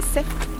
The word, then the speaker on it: Saix: Donc l'arrêt des sexes, S-A-I-X, qui se prononce l'arrêt des sexes, sur notre gauche, par un chemin pentu, mais tout à fait accessible, qui serpente à la fin.